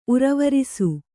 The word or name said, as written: ಉರವರಿಸು